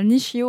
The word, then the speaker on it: Nishio: Le vôtre est récolté à la main à Nishio au Japon, j'espère que je prononce bien. Très bien.